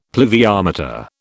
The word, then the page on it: pluviometer: British English